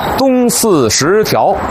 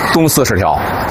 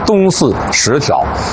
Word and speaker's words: 东四十条: 您肯定听过有人念成东四十条，其实啊应该念成东四十条，因为过去北京城啊从这个东四往北有 14条东西走向的胡同，就是东四头条到东四 14条。这东四十条呢就是其中的第 10条胡同，但现在啊已然拓宽了。